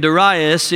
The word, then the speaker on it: Darius: The reason I'm pronouncing his name Darius is because that's the way most Bible scholars pronounce it. And just for once in my life, I want to sound like a scholar.